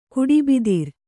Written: ಕುಡಿಬಿದಿರ್

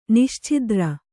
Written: ನಿಶ್ಛಿದ್ರ

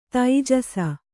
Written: ತೈಜಸ

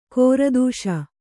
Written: ಕೀರ್ತಿಸು

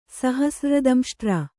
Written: ಸಹಸ್ರ್ರದಂಷ್ಟ್ರ

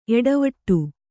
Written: ಏಟವೀಟ

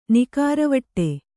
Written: ನಿಕಾರವಟ್ಟೆ

